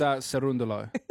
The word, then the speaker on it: Cerúndolo: Cerúndolo? – You pronounced that beautifully. – It's Cerúndolo. I checked it.